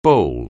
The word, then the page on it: bowl: anglais